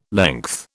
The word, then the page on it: length: British English